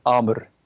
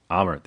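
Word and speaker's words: Àmr: Is it Amar or Amir? – Yeah, Amar. – Amar, there you go.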